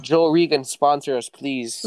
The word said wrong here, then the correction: Joe Rogan: Joe Rogan.